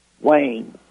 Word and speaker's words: Wayne: And you say your name is Lane? – Wayne. – Wayne. W-A-Y-N-E.